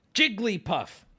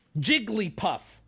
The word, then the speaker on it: Jigglypuff: Jigglypuff. Jigglypuff. She says her name with 3 syllables. One more time for the boys back home. Jigglypuff. Jigglypuff.